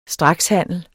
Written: strakshandel

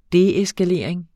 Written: deeskalering

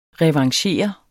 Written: revanchere

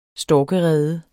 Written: storkerede

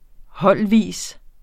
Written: holdvis

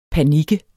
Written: panik

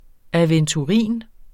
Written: aventurin

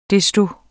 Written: desto

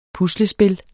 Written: puslespil

